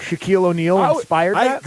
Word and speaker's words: Shaquille: Because when I heard Shaquille O'Neal, never, ever heard of anybody being named Shaquille. – Neither had I. – Yes. – And it's an awesome name. And it's also very cool that it rhymes with O'Neal.